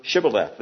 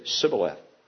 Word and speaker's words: Shibboleth: Say to us the word Shibboleth. They couldn't say it. They could only say Sibboleth.